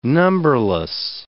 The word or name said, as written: numberless